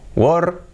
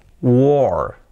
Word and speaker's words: war: W-A-R. ¿Cómo se pronuncia? War. War. ¿Cómo diríais se pronuncia? Yo os lo digo porque es sencillo, se pronuncia war, war. Aquí separamos un poco más los sonidos, sería war, war, war. Ese sonido sería así. Es como realmente como pronunciamos la palabra puerta, door, pero en vez de una D, una W, war.